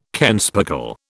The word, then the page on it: kenspeckle: British English